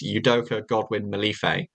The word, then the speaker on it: Udoka Godwin-Malife: But he'd also clarified that the correct pronunciation is Udoka Godwin-Malife. So any other variation is not what he said it is.